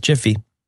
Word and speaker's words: Giphy: I've got Giphy, which should be pronounced Jiffy.